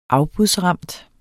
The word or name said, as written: afbudsramt